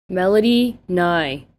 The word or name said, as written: Melody Ngai